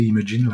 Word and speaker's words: Imagen: Il y a Imagen, ou Imagine, je crois, maintenant ils disent de le prononcer Imagine, là, comme le verbe imaginer,